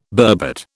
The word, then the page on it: burbot: British English